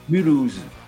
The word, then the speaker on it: Mulhouse: Mulhouse? How do you say that? – Mulhouse.